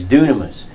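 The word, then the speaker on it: dunamos (δύναμις): It's actually spelled D-Y. And I don't know why, but in Greek it's spelled, it sounds doom instead of dynamite. But anyway, it's dunamos.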